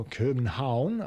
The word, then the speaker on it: Copenhagen: And so some researchers in Copenhagen, or Copenhagen, I believe as it's pronounced in Danish, have made the discovery of a group of molecules called hydrotrioxides.